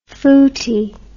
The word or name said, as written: footy